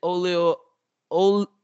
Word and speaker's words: Oyelowo: But next up, we got Rashida Jones and David Oyelowo.